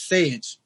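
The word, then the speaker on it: Saje: It's Sage, correct? – Yeah, it kind of rhymes with beige.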